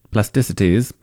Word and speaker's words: Plasticities: Kind of mispronounced it at the beginning, but Plasticities.